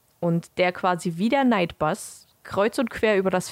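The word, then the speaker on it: Knight Bus: Und was ich noch rausgefunden habe, was ich ganz lustig fand, der Nightbus im Englischen wird ja ausgesprochen wie Nightbus, also wie so ein normaler Nachtbus, wird aber mit K vorne geschrieben, also wie Night.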